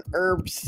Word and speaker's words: herbs: So the H is silent. Herbs.